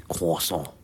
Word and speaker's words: croissant: I keep saying croissant, but you don't say the R, do you? You say croissant.